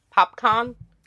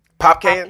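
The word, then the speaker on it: Popcaan: How do you say his name? Popcorn? – Popcane? – Popcane. – I think it's Popcane.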